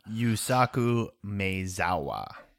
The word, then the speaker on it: Yusaku Maezawa: So the first two are both Japanese, both from Yusaku Mazawa. Sorry, I don't speak Japanese. You'll have to correctly pronounce that. – Yusaku Maezawa.